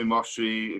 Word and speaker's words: Moshe: Well, everybody calls me Moshe.